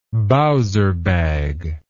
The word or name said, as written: bowser bag